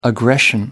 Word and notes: aggression: American English

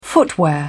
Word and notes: footwear: British English

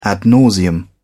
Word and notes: ad nauseam: British English

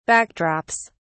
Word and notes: backdrops: British English

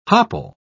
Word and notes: hopple: British English

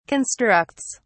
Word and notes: constructs: British English

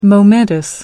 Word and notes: momentous: American English

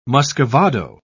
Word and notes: muscovado: British English

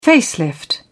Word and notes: facelift: British English

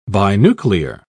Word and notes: binuclear: British English

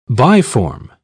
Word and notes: biform: British English

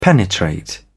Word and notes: penetrate: British English

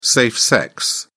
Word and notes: safe sex: British English

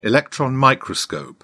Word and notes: electron microscope: British English

